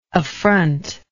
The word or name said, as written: affrontingly